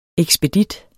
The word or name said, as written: ekspedit